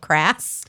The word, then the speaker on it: Krass: It's Lauren Hope Krass. – I love that. – I wish my last name was Krass. It can rhyme with so many fun things.